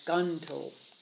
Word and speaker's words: Ganto: Now, Yento is Ganto in the way of pronouncing his characters in Japanese.